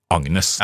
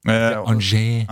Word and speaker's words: Agnes: Det jo fransk uttale på dette navnet her også, men ettersom det finnes norsk uttale på det samme navnet, så går vi for den norske, Agnes.